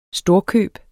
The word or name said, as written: storkøb